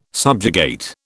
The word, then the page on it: subjugate: British English